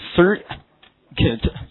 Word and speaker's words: circuit: 好，电路其中这个音标是这样来的，叫做 circuit。 UI 的发音是短的，所以 circuit 这个词表示名词电路啊，记住，就是电子绕了一圈，形成一个闭路的通路叫电路，英文叫 circuit。